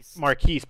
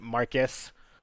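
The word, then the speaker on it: Marques: His name is Marcus Brownlee. But he pronounced Marquise Brownlee? – Marquise. But Will Smith pronounced it Marcus. Like, it sounded like he said Mark Ass Brownlee.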